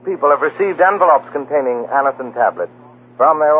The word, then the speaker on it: Anacin: Just ask for Anison at any drug counter. It's spelled A-N-A-C-I-N. Anison.